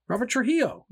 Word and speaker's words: Trujillo: How do you say his fucking name? – Robert Trujillo.